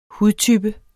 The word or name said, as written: hudtype